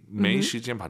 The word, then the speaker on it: 没时间: 저 지금 발음 틀릴 뻔했어요, 여러분. 没时间, 没时间 다 알아들어요. 틀린 발음이에요. 没 아니죠? – 没 왜 올라가야 돼요? 아아 没时, 没时间 이성이 길어야 예뻐요.